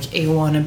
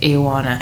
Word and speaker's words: Ioana: I've been figuring out loads of ways, like Ioana build a snowman or iguana, which is basically take the G out and then it kind of sounds like it. Ioana.